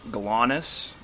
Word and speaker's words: GLONASS: The Russians have something called GLONASS. – Same thing? – That's how you pronounce it.